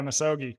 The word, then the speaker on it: misogi: I don't know, am I pronouncing that right? – Yeah, misogi.